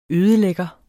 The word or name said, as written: ødelægger